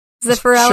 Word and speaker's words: Zeffirelli: Zeffirelli? – Sure, yeah, that works.